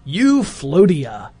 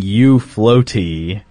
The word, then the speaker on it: Euphlotea: And it's pronounced Euphlotea. – Oh, I was thinking Euphloaty. – Nope. – That's what I got, too. – Euphlotea. It is actually pronounced Euphlotea. – As in, like, utopia.